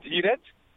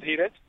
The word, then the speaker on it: Dirich: The name is Dirich. – Forgive me. – I know it's hard to pronounce, but it's Dirich.